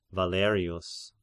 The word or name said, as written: Valerius